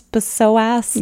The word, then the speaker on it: psoas: And maybe they don't even know how to pronounce it because it's P-S-O-A-S. And you're like, how do you pronounce psoas? – Yeah, the P is silent. Yeah.